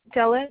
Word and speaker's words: Della: Della, D-E-L-L-A. – All right. Your last name? – Duran, D-U-R-A-N hyphen Estrada.